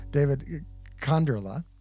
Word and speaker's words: Konderla: David Konderla.